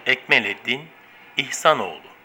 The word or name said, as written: ekmeleddin ihsanoglu